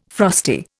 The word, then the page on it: frosty: Indian